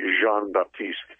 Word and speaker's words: Jean-Baptiste: I got to pronounce it correctly. Stanley Jean-Baptiste from Nebraska.